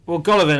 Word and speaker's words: Golovin: I'm excited, from the Russian perspective, I'm excited to see Alexander Golovan, get his name right, I keep calling him Golovkin because there was the boxing on my mind. – Golovin, I mean, he's pound for pound their best player, isn't he, Russia? – Yeah.